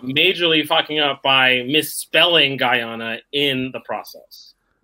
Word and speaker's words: Guyana: Well, it definitely Guyana. Is Guyana. And it's spelled the same way it's pronounced. If you break it up, Guyana.